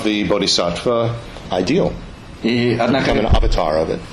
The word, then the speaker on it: avatar: But if you think about it, avatara, that is the Sanskrit word which in Hindi is pronounced avatar.